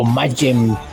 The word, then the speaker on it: Mayhem: Cannibal Corpse y Mayhem, o Mayhem, como ustedes quieran pronunciarlo.